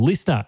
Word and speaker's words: LiSTNR: Listener, L-I-S-T-N-R is how you spell it.